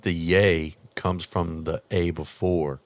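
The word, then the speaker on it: Ye: Yay. – Yay. – It's pronounced yay. I just found out it's pronounced yay, but everybody. – I thought it was yee. – Yeah, I thought it was yee too,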